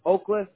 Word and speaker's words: Oklejas: Now, for the account purpose, I have your first name is Victor, V-I-C-T-O-R. What's your last name? – It's Oakless, and it's spelled O-K-L-E-J-A-S. – Okay. Oakless? Is that how you pronounce it? Okay. – Yeah. – And J is silent.